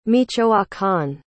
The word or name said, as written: michoacán